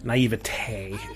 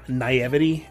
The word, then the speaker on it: naivete: Naivety. I understand why you would say that, and it makes sense because you're just spelling it out. It's naivete is how it's typically pronounced, but naivety is fine.